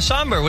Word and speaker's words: Sombr: Somber, S-O-M-B-R. – Oh, yeah, Somber.